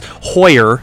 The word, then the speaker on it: Hoyer: I almost said horror there for a sec. He's probably got his name mispronounced so many times. Hoyer is what I meant to say.